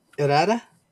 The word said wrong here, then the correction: Herida: Herida. – There you go. Sorry about that. All you people, I'm not hashing it up. – Just think of ear and say Herida.